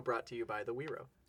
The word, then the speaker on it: guiro: That's called a Wiro, spelled like G-U-I-R-O.